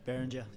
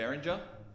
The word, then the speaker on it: Behringer: I had to figure out how to use this Behringer. Is that how you say it? – Behringer. Yep. – Behringer.